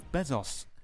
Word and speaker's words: Bezos: In other news, Jeff Bezos. How do you say his name? – Bezos.